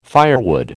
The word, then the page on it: firewood: American English